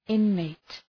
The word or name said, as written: inmate